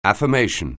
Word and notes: affirmation: anglais

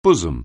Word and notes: bosom: anglais

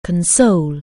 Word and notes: console: anglais

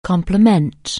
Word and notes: complement: anglais

